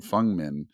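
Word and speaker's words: Feng Min: Like I ran into, I'm getting used to saying Fengmin. That's how you pronounce her name. Fengmin.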